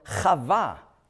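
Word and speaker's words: Chava (חוה): Er nennt sie nicht Mavet, sondern Chava, wie du in der Fußnote gelesen hast, also Eva, hebräisch ausgesprochen.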